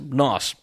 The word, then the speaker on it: Gnoss: I've been told for the longest time I was pronouncing it wrong. I was always calling them GNOS. But the G is silent. So G-N-O-S-S, NOS.